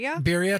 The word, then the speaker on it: birria: Okay, well, the geese that I breasted out after our hunt, I made, I might pronounce this wrong, birria. – Birria tacos.